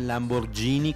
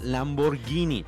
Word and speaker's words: Lamborghini: Ducati ha develado el resultado de una colaboración con Lamborghini porque la pronunciación de esta marca no es Lamborghini como mucha gente cree. Porque ven la G con la H y dicen, ah, Lamborghini. Pues no, no, señores, es Lamborghini.